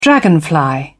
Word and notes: dragonfly: British English

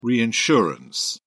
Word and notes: reinsurance: British English

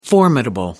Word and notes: formidable: American English

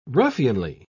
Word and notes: ruffianly: British English

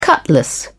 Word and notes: cutlass: British English

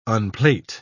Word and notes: unplait: British English

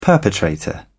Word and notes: perpetrator: British English

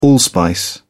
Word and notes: allspice: British English

